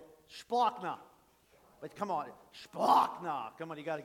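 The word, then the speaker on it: splanchna: Splachna. Sounds like something Worf would say on Star Trek. Klingon, but it's not. Klingon, no, it's Greek. Splachna. S-P-L-A-N-C-H-A. That's how you say it.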